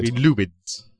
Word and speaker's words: Lumines: But it doesn't matter, because Q Entertainment, or whatever the company is called that makes Lumines, is Japanese, so you have to abide. – Lumines. – Yeah, you have to abide by Japanese pronunciations.